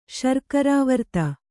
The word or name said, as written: ಶರ್ಕರಾವರ್ತ